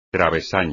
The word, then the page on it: travesaño: espagnol